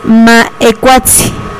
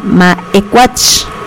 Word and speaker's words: Maecuatzi: Muchas de las veces hemos escuchado que se pronuncia Maecuatch, Maecuatch, ¿sí? Y no, no es Maecuatch, es Maecuatzi, sí, etzi, no es con tse, sino con tsi, ¿sí? Porque si lo pronunciamos con tse, estamos diciendo otra cosa.